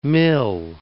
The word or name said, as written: mil